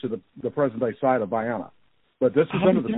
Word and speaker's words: Vienna: Or what, you know, non-locals, how you should just call Vienna, which is not, it's not pronounced that way. Up here it's pronounced Vienna, but it's named for Vienna, Georgia.